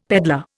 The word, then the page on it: pedlar: Indian